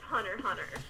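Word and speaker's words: Hunter x Hunter: JT is the one who goes, no, you don't pronounce the X. Just Hunter, Hunter.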